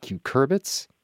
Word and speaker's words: cucurbits: Is it cucurbits? – Yeah.